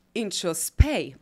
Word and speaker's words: Inchauspé: Inchauspé. Hi. – Am I saying this right? You're saying it perfectly.